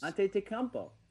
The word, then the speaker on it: Antetokounmpo: Giannis Antetokounmpo. – Antetokounmpo. – I just said it perfectly. – I just got to be honest. – Antetokounmpo.